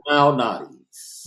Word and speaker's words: Malnati's: Lou Malnati's.